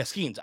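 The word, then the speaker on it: Skenes: Skeet, whatever. It doesn't matter. You don't need that. What you need. Yeah, Skeens. I did say his name wrong.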